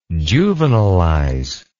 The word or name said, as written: juvenilize